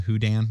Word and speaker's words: Houdan: I have no idea if I'm pronouncing that right. Howden, Howdan, Houdan, whatever. Houdan. Houdan.